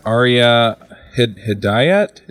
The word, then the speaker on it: Ariya Hidayat: We also have a special guest, and that's Ariya Hidayat.